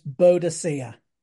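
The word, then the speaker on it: Boudica: Well, when I was at primary school, she was pronounced Boadicea. Now they seem that they want to pronounce her Boadica.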